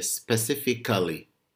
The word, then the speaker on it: specifically: They pronounce the word wrongly because this kind of word that has A, like C-A-L-L-Y at the end, try to mute the A. So this word, I hear students will say specifically.